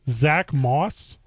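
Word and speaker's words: Zack Moss: Zach Moss? – Yes.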